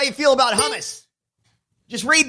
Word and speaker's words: hummus: He said hummus. He just got the stress wrong. The sounds were correct.